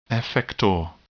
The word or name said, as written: effector